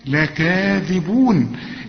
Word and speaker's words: لكاذبون: إنهم، شوف النون غنتها إزاي وما تنساش الغنة، إنهم لكاذبون، الكاذب بعدها ألف أهي، لكاذب تكتب هكذا لكن تنطق بالألف، لكاذبون.